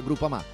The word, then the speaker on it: Groupama: Groupama, ah no, si pronuncia Groupama, di Paolo Felicetti.